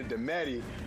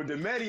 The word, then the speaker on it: Dometi: If they go from Domati to Dometti, that just means they knew me before everyone knew how to pronounce my name. So Demetti is almost like a nickname.